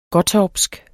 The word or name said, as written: gottorpsk